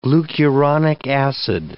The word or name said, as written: glucuronic acid